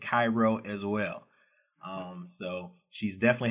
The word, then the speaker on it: Cairo: Sometimes people pronounce that wrong. You know, people pronounce it Cairo, like the Egyptian version. But yeah, it's Cairo, the only spot where people pronounce it that way, Southern Illinois.